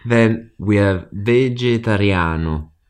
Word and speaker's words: vegetariano: Sono vegetariano. – And here, similar to in English, the spelling, we've got G plus E. So we've got this nice soft J sound in the middle.